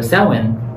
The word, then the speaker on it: Samhain: And so Samhain is spelled S A M H A I N, but it's pronounced Samhain, and that's because it comes from Gaelic, and so some vowels are dropped, or rather, some consonants are dropped, some Bells are added or whatever the case may be.